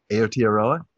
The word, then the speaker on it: Aotearoa: Did I do it? Aotearoa? Nailed it.